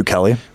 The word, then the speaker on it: Bukele: Bukele.